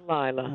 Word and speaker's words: Laila: L-A-I-L-A. – My sisters are Biba, Dinah, and Zyga. – So all right. – It's Lila.